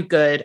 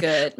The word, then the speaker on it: Good: Matthew Goody is playing, or Matthew Good. I don't know how you pronounce his last name. – Good. Matthew Good.